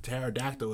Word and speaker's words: pterodactyl: I don't know why they spell pterodactyl with a P.